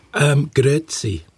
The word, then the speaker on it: Grüezi: Grüezi.